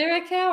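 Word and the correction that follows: Curaçao: or Curacao? – I don't know. Curacao. I think it's Curacao with that U.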